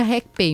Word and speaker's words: RekPay: A gente pronuncia RecPay, mas escreve R-E-K-P-A-Y.